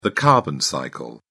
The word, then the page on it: carbon cycle: British English